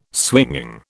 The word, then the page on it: swinging: British English